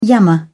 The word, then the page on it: yammer: British English